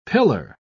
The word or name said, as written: pillar